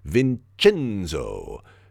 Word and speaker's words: Vincenzo: By the way, he did have something of a Jay Leno jaw, but he got the nickname from his mom, who always called him Vincenzo and put a hard accent on that middle syllable.